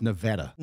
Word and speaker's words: Nevada: It's pronounced Nevada. – Nevada. – Yes. Nevada. I just learned that.